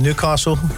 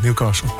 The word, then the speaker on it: Newcastle: Kijk, wij spreken de T niet uit. – Nee. – Dus eigenlijk, als je uit het zuiden komt, zeggen we Newcastle.